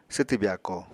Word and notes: swtw byako: apatani

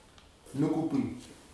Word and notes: nukubi: futuna aniwa

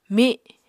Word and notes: mi: Apatani

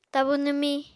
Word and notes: tabu nwmiñ: Apatani

